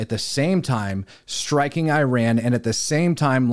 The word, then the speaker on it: Iran: Now, I have been told the proper way to pronounce Iran is Iran, and I'm going to truthfully tell you, I don't give a crap.